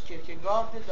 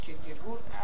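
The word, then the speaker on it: Kierkegaard: در باب اینکه کیرکگور یا کیرکگارد تلفظ باید بشه، اختلافی در تلفظ دانمارکی و غیر دانمارکیش هست. دارن تلفظهای غیر دانمارکیش کرکگارد، دانمارکیش کرکگورد است.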